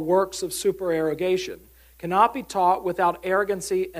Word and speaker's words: supererogation: Free cup of coffee to anyone who can pronounce this word. Supererogation. Well, there you go. Super. So let's try it. Super. It's like erogation.